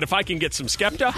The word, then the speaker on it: Skepta: Rumor has it that Adele and Spekta are dating. – Oh, I love Spekta. – No, wait, I said it wrong. Skepta.